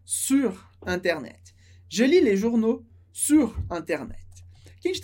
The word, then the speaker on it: journaux: A pronúncia sempre, sempre vai ser o. Então, le journal, les journaux. Journaux.